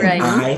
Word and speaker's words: Coni: Is spelled Coni, not Connie, Coni.